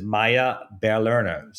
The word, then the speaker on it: Maya Lerner: So I'm really super excited to welcome the CEO of Chiefy, which is Maya Lerner.